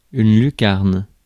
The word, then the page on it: lucarne: France; /ly.kaʁn/